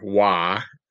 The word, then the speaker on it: Roy: The Roy, or Wah, is how they pronounce it, just like Patrick Wah, the Avalanche goalie from years back and the Avalanche owner manager more recently.